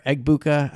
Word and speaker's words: Egbuka: Obviously, you know, Mbuka is a really good player, but or Egbuka, I should say.